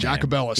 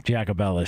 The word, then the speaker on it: Jacobellis: And she was the one, Lindsay, I can't pronounce her last Jacobellis. – Name. Jacobellis. Yeah, Jacobellis.